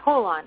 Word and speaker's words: holon: So we're also waiting for Ken to call in, but I think to just start out, the word is pronounced, tell me, Holon? – Yeah, Holon, H-O-L-O-N, Holon, yeah.